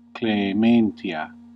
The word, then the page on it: mercy: /ˈmɝ.si/; /ˈmɜː.si/